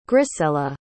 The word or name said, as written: grisella